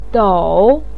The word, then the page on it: dou3: pinyin